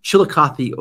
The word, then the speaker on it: Chillicothe: So Mark is the founder and senior pastor of Open Door Church in Chillicothe, Ohio. – You said that right.